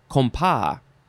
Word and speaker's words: compas: Next is, it is pronounced compas. Compas, C-O-M-P-A-S.